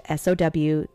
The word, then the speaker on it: Samhain: Samhain is a pagan religious festival originating from an ancient Celtic spiritual tradition. In modern times, Samhain, a Gaelic word pronounced S-O-W-W-I-N or Samhain,